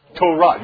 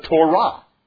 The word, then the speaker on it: Torah: When you read in the Old Testament about the law of God, the Hebrew word is Torah. Say it with me, please. Torah. You've heard of Torah. Well, it's literally pronounced the Torah.